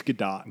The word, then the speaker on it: Gadot: Gadot's great. – Gadot. Is that how you pronounce it? Is it Godot or I don't know? – I think it's Gadot. – It's Gadot.